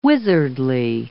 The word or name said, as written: wizardly